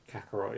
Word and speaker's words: Kakarot: Is Dragon Ball Z Kakarot? – Kakarot, yeah.